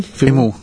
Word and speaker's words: Fimmel: Fimmel.